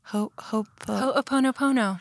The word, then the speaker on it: Ho'oponopono: Ho'oponopono.